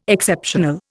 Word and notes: Exceptional: Indian

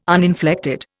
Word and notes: uninflected: Indian

